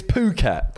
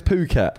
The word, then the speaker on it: Phuket: It's Phuket. – Phuket. – It's Phuket. – Phuket. – That place is pronounced Phuket.